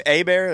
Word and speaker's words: Hebert: Hebert? That's how you say it? It was French? – Yeah.